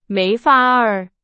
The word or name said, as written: 没法儿